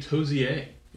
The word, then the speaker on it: Tozer: Okay, we know how to pronounce Tozer. Is it Towser? – Most people say Tozer. – Yes. Oh. I'm with most people, but I'm not right. – But it can also be Tozier.